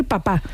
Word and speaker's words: papá: Desde la lengua francesa, en el siglo XIX, se fue extendiendo lo de decir papá. Y en un principio era una pronunciación como muy afectada, como digo, de pijo. Pero es la que se ha terminado generalizando en la lengua estándar.